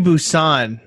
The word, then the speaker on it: Busan: Train to Busan or Busan or Busan. Fucking tell me that I said it wrong. – Yeah, you said it right.